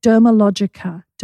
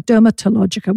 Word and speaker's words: Dermalogica: Dermalogica. Dermalogica. Because everyone started saying Dermatologica. We'd say the T is silent.